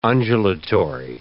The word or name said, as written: undulatory